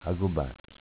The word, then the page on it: Agobass: dialetto